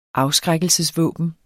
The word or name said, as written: afskrækkelsesvåben